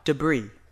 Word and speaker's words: debris: debris, debris, debris. – 파편, 잔해. – 조심해야 될 거는 S는 단수일 때는 묵음이에요. 그러나 복수일 때는 파편들 할 때는 debris라고 읽어야 돼요.